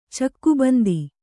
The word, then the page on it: ಚೌಕಬಂಧ: cauka bandha